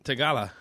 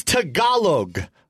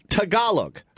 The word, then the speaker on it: Tagalog: Tagala. – Okay. I've taught how to say the language 95 times. I think you are choosing to say it wrong because it's funnier. It's Tagalog. Tagalog.